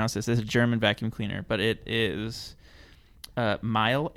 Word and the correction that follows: Miele: M I E L E. – Miele.